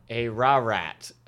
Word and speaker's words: Ararat: A rat. Nope. I said it wrong. A ra-rat. Oh, boy. A-R-A.